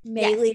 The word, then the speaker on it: Maylis: Maylis, yes, rhymes with daily.